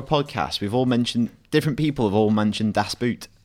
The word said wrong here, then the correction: Das Boot: It's not Das Boot. – Okay. So, but think of this. boat when you pronounce boot, like das boat, and you're 95% there, which is good enough for me.